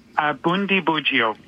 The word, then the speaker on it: Bundibugyo: Bundibugyo.